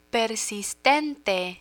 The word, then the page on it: persistente: Spanish